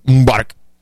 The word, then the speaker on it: Mubarak: He says, you know, what happened was that Mubarak, remember, you've got to pronounce it properly if you want to sound credible, Mubarak, he tried to change the constitution to allow his son to succeed him.